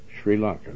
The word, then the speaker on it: Sri Lanka: I don't even know how to pronounce it right, let alone, but it's, how do you pronounce that? – Sri Lanka. – Sri Lanka, that's what I said.